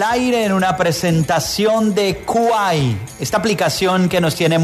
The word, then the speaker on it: Kwai: Se escribe, se dice Kuai, pero se escribe K-W-A-I latina. K-W-A-I latina.